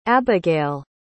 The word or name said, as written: Abagael